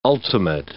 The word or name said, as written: ultimate